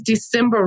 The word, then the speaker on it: Desimber: Well, my name is Desimber Rose, and that is spelled D E S I M B E R, and then Rose.